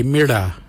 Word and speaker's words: mirda: Walang is spelled W-A-L-Y-A-N. Walang. Walang is blue. The next colour is red. In our language we say mira. Mira is red. Mira is spelled M-I-R-D-A.